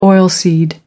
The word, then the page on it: oilseed: American English